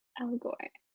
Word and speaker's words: Elgort: Okay, so this Ansel Elgort, how do you say his last name? – I think you're saying it, Ansel. I think you're saying it right. – Elgort.